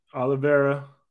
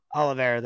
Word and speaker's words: Oliveira: That's Olivier and Oliveira. – Oliveira, that's how you say it, sir.